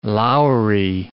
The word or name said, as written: loury